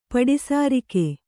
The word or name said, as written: ಪಡಿಸಾರಿಕೆ